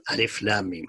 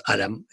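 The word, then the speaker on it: الم: So Al-Baqarah starts with the strange three letters, Alif, Lam, Mim, and that's the way it's pronounced. It's not Alam, it is Alif, Lam, Mim.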